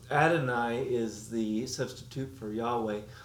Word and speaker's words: Yahweh: In Hebrew, we would call that, or we would attempt to pronounce that, Yahweh.